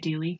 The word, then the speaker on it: Dealey: So let's get to the aftermath at Dealey Plaza. Is that how you say it, Dealey? – Mm-hmm.